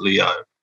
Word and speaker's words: Liu: I was going to say his name wrong, so I watched a few interviews with him, and he pronounces it Leo. So yeah, I was really paranoid. I was going to muck it up. So just in case, I looked. – So he actually pronounces it Leo.